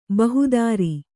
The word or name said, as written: ಬಹುದಾರಿ